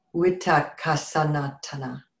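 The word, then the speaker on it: Vitakkasanthana: But this one is the Vittakasatana, or sometimes if it's in Pali, Vittakasatana. Oops, I just screwed it up. The V is pronounced W. Vittakasatana. It's V I T A K K A S A N T H A N A.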